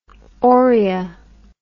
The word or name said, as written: Oria